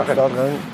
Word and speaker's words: Rennes: Zu Hause gegen, jetzt wusste ich, dass ich es nicht aussprechen kann, Renn, oder? Renn. – Ja, Rennes.